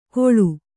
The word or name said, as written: ಗೋಳು